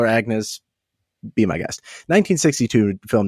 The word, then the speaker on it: Agnès: And I did look up the pronunciation. It's Agnès.